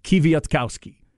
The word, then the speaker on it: Kwiatkowski: Five sounds, Kwiatkowski. And again, punch the cow. Next to last syllable is where they put the emphasis.